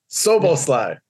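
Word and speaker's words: Szoboszlai: And also Soboslai.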